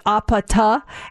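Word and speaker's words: apata (아파트): And in Korean, it's pronounced apata, and that's what they're saying in the song's chorus.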